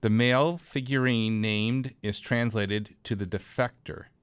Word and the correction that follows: defecator: And no, actually, I didn't say that right. The male figurine's name is translated into the defecator, which makes sense since the porcelain doll is squatting with his pants down.